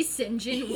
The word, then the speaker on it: St. John: Which St. John is pronounced is not pronounced. It is spelled Saint John.